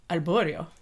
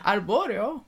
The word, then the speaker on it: Arborio: Arborio. – Yeah. – Arborio.